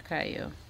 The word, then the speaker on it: Caillou: I mean, Caillou.